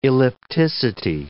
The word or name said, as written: ellipticity